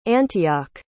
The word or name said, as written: antioch